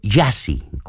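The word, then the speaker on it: Gyasi: Se llama Jiassi, pronunciado Jiassi, como él mismo explica en el título de su último álbum.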